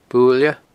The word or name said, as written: Buaile